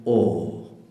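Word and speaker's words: awe: La palabra temor en inglés se escribe A-W-E y se pronuncia O.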